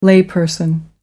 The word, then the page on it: layperson: American English